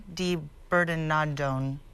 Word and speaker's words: di Bernardone: How do you pronounce that, huh? – Mr. Italian? – Di Bernardone.